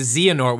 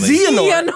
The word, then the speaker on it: Xehanort: No, it's actually Xehanort. We can finally just say it properly. – Just Xehanort. – Xehanort.